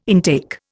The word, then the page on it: intake: Indian